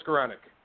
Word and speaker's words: Skowronek: Joe Skowronek.